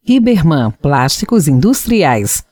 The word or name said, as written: Riberman